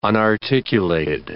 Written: unarticulated

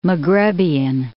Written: Maghrebian

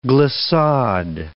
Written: glissade

